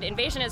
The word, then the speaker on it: INVSN: INSVN is pronounced INSVN, but it's spelled I-N-V-S-N for our listeners. Why'd you guys decide to leave out some vowels? – Well, we're a Swedish band, and we used to be called INSVN, which is a Swedish name, and it's spelled a bit, it's spelled like invasion with the E-N at the end.